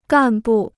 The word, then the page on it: 干部: gàn bù